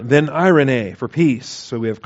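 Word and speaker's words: Irene (εἰρήνη): The accent's on the first eta there, so it's Irene, E-I-R-E-N-E. Epsilon iota rho, eta nu eta, E-I-R-E-N-E. Accent on the middle syllable, Irene.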